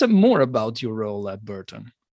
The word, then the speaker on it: Burton: we call it Barton. That's how we call it in Italy. How do I pronounce it? Then I get to the question. How do I pronounce it correctly? I never said it. – Burton. – Burton. Burton. Very different.